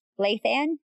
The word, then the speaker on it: Lathan: Lathan? – I was just checking. – Lathan. – Just making sure we get our... – Lathan. – Our guest's proper intro. – Yes, we have Van Lathan here, and it is bringing us back to our fame days.